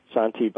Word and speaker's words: Santé: Santé, by the way, is Swahili for health. French, pronounced santé as well.